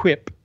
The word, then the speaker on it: WIP: Uh, we pronounce that whip. It's a whip.